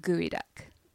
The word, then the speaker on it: geoduck: You would think that it should be pronounced geoduck because it's G-E-O duck, but it's pronounced gooey duck.